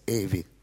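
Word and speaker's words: aevi: А происходит оно от двух латинских слов «медии эви» – «средние века». «Медии» – два «и» с точкой, и «эви», которая пишется «аэ», а читается «эви».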